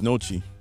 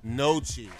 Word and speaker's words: gnocchi: G-N-O-C-C-H-I. How do you say that? – I think it's Nochi. – Nochi. G is silent.